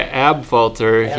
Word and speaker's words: Abfalter: This is Troy Abfalter here checking in, and I am ready to rock.